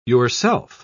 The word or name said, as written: yourself